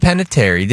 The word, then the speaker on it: Panettiere: Hayden Panettiere.